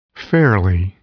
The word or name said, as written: fairly